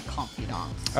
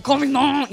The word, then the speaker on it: confidant: They're my confidants. – Oh, it sounds kind of French. – Confidant. You have to say it like that if you say it.